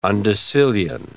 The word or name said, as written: undecillion